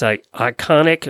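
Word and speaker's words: Iconeq: Emily recommends a company called Iconic Designs, and it's Iconic. It's spelled so cute, the name, how they put it in there. – It's I-C-O-N-E-Q Designs.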